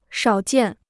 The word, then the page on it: 少见: shǎo jiàn